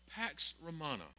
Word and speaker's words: Pax Romana: But because of this little thing called Pax Romana. Did I say that right, history teacher? Someone, okay. I said it right, Hunter.